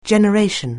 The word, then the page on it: generation: anglais